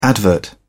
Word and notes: advert: British English

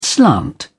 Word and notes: slant: British English

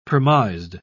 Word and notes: premised: British English

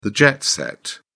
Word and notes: jet set: British English

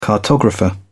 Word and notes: cartographer: British English

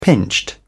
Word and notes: pinched: British English

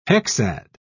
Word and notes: hexad: British English

